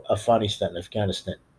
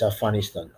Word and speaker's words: Afghanistan: One thing that we had that's really a nightmare is NATO and the United States pulling out of Afghanistan, As my friend Samira always says, you're pronouncing it the wrong way. It's Afghanistan.